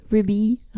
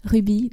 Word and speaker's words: Ruby: On peut autant le prononcer en anglais qu'en français, Ruby, Ruby.